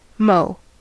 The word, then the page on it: mow: American English